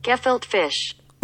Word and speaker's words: gefilte: How do you say kafilka fish. There it is. – Gefilte fish. – It's G-E-F-I-L-T-E. – What the hell? – Gefilte fish. – Mm. – With a G. Mm. Not one K in there, Shopmaster.